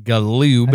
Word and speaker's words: Galoob: In the 1990s, a company called Galoob, which is spelled the way I just pronounced it, Galoob.